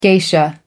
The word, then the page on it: geisha: American English